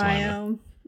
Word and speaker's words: Lima: It's Lima.